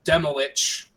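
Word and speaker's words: Demilich: And actually, it's pronounced Demolich. I found that. – I actually knew that. – Yeah, we were all calling him Demolik, and then they were like, "We are Demolich," and I was like, "I'm a poser."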